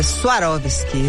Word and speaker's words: Swarovski: Ela fala Swarovski. Essa é a pronúncia correta aí para quem quiser ser precioso.